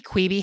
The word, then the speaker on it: Quibi: Quibi, how do we say this? – Quibi.